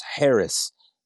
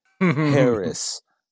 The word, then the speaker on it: Haracz: So, I mean, you could find me on all the socials, and my last name is spelled just like it sounds. Harris, H-A-R-A-C-Z, Harris.